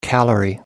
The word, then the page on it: calorie: American English